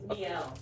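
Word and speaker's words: Miele: Malay. What is it, Malay? – It's the vacuum you want. It is fucking awesome. And we have had the same Malay. – It's Miel. – Miel, is that how you say it? – But we're still cooking with KFC over here.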